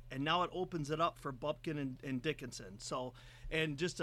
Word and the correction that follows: Bufkin: I love that you cannot pronounce Bufkin. – Bufkin. – You said it. You said Bupkin.